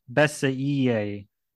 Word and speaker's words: Bessey: And remember, everyone, it's pronounced Keven Bessey.